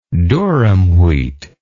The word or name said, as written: durum wheat